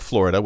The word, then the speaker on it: Flo Rida: His name is spelled like Florida with a space and an extra capital, but it is Flo Rida.